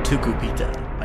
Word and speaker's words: Tucupita: Playing second base for the Fort Wayne Tin Caps, Tucupita Marcano. – Tucupita. I just wanted to say that again. – Learned how to say his name properly now and just loves saying his name.